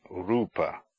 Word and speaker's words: rupa: Long u, u, is like the u in rune, as in rupa, form.